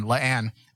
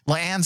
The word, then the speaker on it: La'an: I think it's La'an. It's spelled L-A-apostrophe-A-N. La'an. La'an Sing.